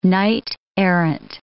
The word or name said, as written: knight-errant